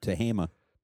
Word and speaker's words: Tehama: Is that how you say it? – Yep, Tehama.